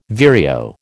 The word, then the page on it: vireo: American English